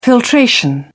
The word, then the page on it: filtration: British English